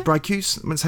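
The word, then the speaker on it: Bricusse: Which can actually bring us neatly back to this, because is it Leslie Bracusi? Is that how you, is that how you say it? Brycuse, I think, isn't it? – Brycuse, how you say his name.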